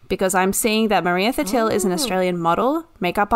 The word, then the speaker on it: Thattil: Maria Thatil. T-H-A... – Can you spell it? Yeah. – T-H-A-T-T-I-L. Maria Thatil.